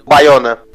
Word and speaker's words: Bayona: Eu não sei pronunciar o nome dele, né? Boyaga. – Bayona. – Tipo assim, Isso.